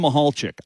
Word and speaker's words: Mahalchik: Jim Mahalchik. I know I have that one right. You know, it's a confusing spelling, but that's how you say it.